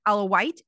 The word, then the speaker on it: Alawite: Primarily the Alawite, is that how you pronounce it? – Yes.